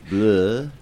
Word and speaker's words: bleu: Blue cheese. Pronounce it bleh.